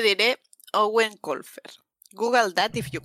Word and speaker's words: Eoin: obviamente, si se llama Owen, se escribe Eoin.